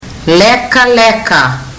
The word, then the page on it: lekaleka: fiji